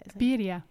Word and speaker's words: birria: We have a lot of tacos and great margaritas and Aunt Margaritas and the birria. How do you say the birria? – Birria.